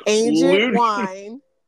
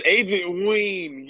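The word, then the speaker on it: Weine: Rivers, Carter Horton, Valerie Luton, Agent Wine. – No, it's Agent Ween. Get it right. – Ween. Oh, sorry. I forgot how they pronounce it.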